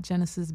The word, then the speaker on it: Genesis: It's just at Genesis B. You spell that G-E-N-E-S-I-S, the letter B, the letter E, and that's Twitter, Instagram, everywhere.